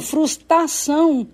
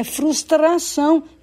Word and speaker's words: frustração: Ou falam frustração e próprio, faltando R, quando o correto é frustração e próprio.